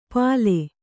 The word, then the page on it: ಪಾಲೆ: kannada